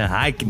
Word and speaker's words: Heineken: A Heineken, que sai com a pronúncia... – É Heineken.